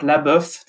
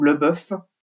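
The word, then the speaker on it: LaBeouf: D'ailleurs, on peut dire les deux prononciations. C'est lui-même d'ailleurs qui l'a indiqué. Donc Leboeuf, attaché ou Leboeuf.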